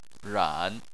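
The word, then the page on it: ran: tone 3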